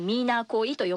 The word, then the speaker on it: 3751: はい、このように、実際に女性ならではの目線というところからもね、いろいろなお話を伺うことができました、はい、でこちらちょっとスタジオにもご用意したんですけれども、島ピクルスフロム 3751 と書いて、これミーナーコイと読ませるんですけれど、これもね地元のお母さん世代の方々と協力して、こんな新たな事業にもチャレンジされてるんですね。